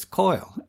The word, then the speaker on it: Kyle: That's spelled K-Y-L-E, but he pronounces it, Gordon Shepherd, pronounces it as Coil in the song.